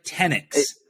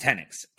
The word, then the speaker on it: Tenyks: Am I pronouncing that right? Tenyks. – It's just Tenyks. – Okay, just Tenyks. I gotcha.